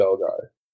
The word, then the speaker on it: Belgae: Belgae or Belgae? – It's well, A is a diphthong. It sounds like I, so it would be Belgae.